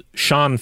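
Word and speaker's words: Siân: one of which, I believe I said Cyan Phillips. It is Sean Phillips.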